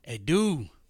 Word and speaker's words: Adoo: So let me tell you about Miss Wendy Adoo.